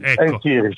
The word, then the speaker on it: En-Nesyri: En-Nesyri si pronuncia, En-Nesyri.